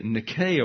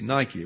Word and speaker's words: Nike: And so I call them the Nike Award, would be the Greek pronunciation, but we have these tennis shoes that require us to say the Nike Awards.